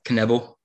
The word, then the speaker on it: Knebel: Knebel? – Yeah.